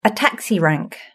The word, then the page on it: a taxi rank: British English